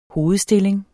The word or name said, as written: hovedstilling